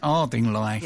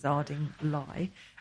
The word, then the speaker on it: Ardingly: She pronounced the place correctly. – It's all wrong. It should be Ardingly, but it's Arding. – It's Ardingly. – Yes, my co-presenter says it's Ardingly, and that's in West Sussex.